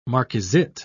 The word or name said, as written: marquisette